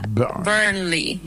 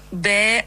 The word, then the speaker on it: Burnley: Se pronuncia Burnley. La primera parte suena como burn. Con el acento ahí. – Uh-huh. – En IPA es Burnley.